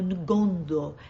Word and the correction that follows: Ndongo: É muito difícil falar essas palavras porque tem sempre sempre um N mudo no meio, não sei pronunciar, mas é Ndongo.